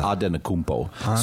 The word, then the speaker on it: Antetokounmpo: So the official spelling was Antetokounmpo. So you just say it how it looks.